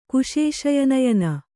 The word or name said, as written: ಕುಶೇಶಯನಯನ